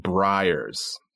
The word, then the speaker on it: Breyers: Yeah, and then my mother-in-law, 我婆婆一直家里有的一个叫 Is it Breyers or Breyers? – I pronounce it Breyers.